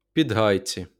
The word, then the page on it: Підгайці: pidˈɦɑjtsi